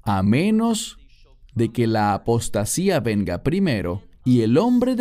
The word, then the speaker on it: apostasía: La palabra aquí es apostasía, en griego, que se pronuncia igual, apostasía.